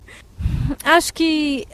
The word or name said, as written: acho que